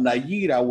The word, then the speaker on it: Nayirah: Nayira? N-A-Y-I-R-A-H.